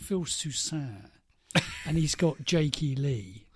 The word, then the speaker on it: Jake E. Lee: And he settles on a new guitarist, Mr. Jake E. Lee. I think that's how you pronounce it, isn't it? – Jake E. Lee? – Yeah.